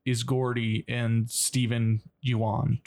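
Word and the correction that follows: Yeun: Did I say his name right? How do I say his name? – Oh, it's Steven Yuen, but...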